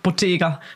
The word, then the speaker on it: Bottega: Trägt sie Yves Saint Laurent, trägt sie Chanel, trägt sie Bottega? Spricht man das so aus? – Bottega. – Bottega.